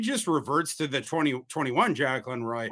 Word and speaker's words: Jaquelin: And the Jack, it's the Vikings press team put it out that it's like Jaquelin. So I've been mispronouncing his first name for years, but I don't even remember how the real one. So I just got to call him Jaquelin.